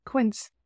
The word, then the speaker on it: Quince: Quince, it rhymes with Prince, which I love because I am a huge Prince fan and also a very big Quince fan.